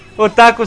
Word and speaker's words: otaku: E aí aqui no Brasil, como a palavra japonesa já misturou e acabou que otaku virou uma expressão aqui. – Mas é otaku ou otaku? – Me falaram que não, que não tem sílaba tônica nenhum, que pra mim é impossível.